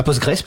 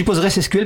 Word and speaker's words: PostgreSQL: à Postgres putain, pourquoi? Alors je dis PostgreSQL parce que ça s'écrit SQL à la fin, Postgres. – On peut dire les deux.